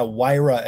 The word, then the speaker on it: Huayra: Huayra, as if it were wire. W-I-R-E. – Huayra. Oh, so the H is silent.